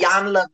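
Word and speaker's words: Iyanla: and it's by, I don't know how to pronounce, is it Ilyana, y'all? – Yep. – Okay, yes. – Ilyana Van Zandt.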